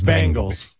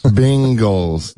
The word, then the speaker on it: Bengals: It's just the pronunciation is, it is, Robin is technically correct. It is Bengals. Bengals. – Bingles.